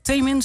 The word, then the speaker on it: Taemin: Nu heb je ook, en dat is ook wel bijzonder, heb je samengewerkt met een K-pop artiest, dat is Taemin, zeg ik dat goed? – Ja, dat zeg je goed.